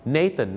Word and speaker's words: Nathan: ฝากไว้หนึ่งชื่อครับ Nathan นะครับไม่ใช่นาธานครับ